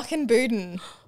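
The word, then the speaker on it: Muckinbudin: Good try. – Oh, close. – No. – Muckinbuden. – I even phonetically wrote it out too. I was so close. Okay, no. I'm glad you corrected me.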